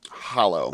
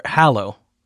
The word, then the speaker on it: Hallow: Hollow. – Hollow, yes. So, or Hallow is how you... – Hallow. – Yes. – As you really, really pronounce it, is Hallow.